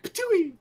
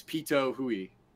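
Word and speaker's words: Pitohui: See, when I see this name, I think Patooie, as in like Spittoon Patooie. – Yes, I can see why. – But that's not exactly how it's pronounced. But it's Pito Hui, and her short Pito for short.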